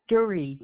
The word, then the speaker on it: Duree: Jovan Duree, D-U-R-E-E. – Just hold on. The first name, how do I spell that? – Jovan, J-O-L-Y-N-N-E.